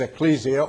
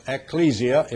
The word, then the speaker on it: ecclesia: The word church is ecclesia, or ecclesia, if you prefer, or ecclesia, some people pronounce it.